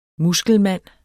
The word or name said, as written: muskelmand